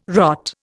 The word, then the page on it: rot: Indian